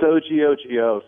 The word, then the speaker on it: OGOGO: It's OGOGO, so it's like the word OGOGO with a wrong letter.